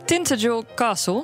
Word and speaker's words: Tintagel Castle: Daar staat namelijk Tintagel Castle, zo spreek je dat uit.